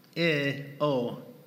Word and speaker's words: energeo: Energeo. Energeo. That's how it's pronounced in the Greek.